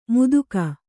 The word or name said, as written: ಮುದುಕ